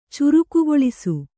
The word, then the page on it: ಚುರುಕುಗೊಳಿಸು: Kannada